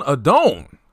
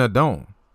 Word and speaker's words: Adon: But Joan Adone, I said his name wrong. His name is Joan Adone. It's not even Adone. It's Adon. It's Adon. No, it's it's a, it's Adon. Like like Anthony Rendon, like this guy's a don.